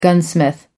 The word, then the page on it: gunsmith: American English